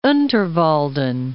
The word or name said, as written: Unterwalden